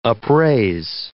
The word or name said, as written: upraise